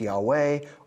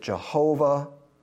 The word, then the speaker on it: Yahweh: Anytime you see the capital L-O-R-D, that's actually the proper name. That's Yahweh or Yahweh. We pronounce it Jehovah.